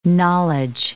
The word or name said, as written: knowledge